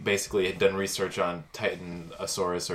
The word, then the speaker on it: Titanosaurus: Start to say Titanic, then say Saurus. – Titanosaurus. – Oh, now I want to see that version. Yes. Titanosaurus, where the boat is a dinosaur.